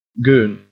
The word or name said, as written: goon